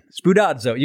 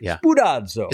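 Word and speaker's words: Spoudazo (σπουδάζω): I put it on my Instagram recently because I just think it's such a cool word called spoudazo. Have you heard of this word? – Go ahead. – Spoudazo. You got to say it like you're Italian. Like, spoudazo.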